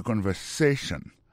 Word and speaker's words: conversation: Mot conversation, accent tombé sous avant-dernier syllabe là. You know? Listen to the conversation.